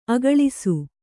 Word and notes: ಅಗಳಿಸು: kannada

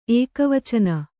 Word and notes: ಏಕವಚನ: ēkavacana

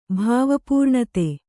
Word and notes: ಭಾವಪೂರ್ಣತೆ: Kannada

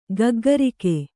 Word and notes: ಗಗ್ಗರಿಕ್ಕೆ: gaggarikke

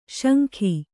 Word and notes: ಶಂಖಿ: śankhi

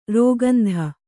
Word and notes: ರಾಕೇಂದು: rākēndu

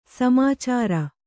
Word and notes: ಸಾಮಾಚಾರ: sāmācāra

